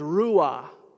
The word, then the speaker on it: ruah: And the Hebrew word for it is ruah, R-U-A is how we'd spell it, ruah.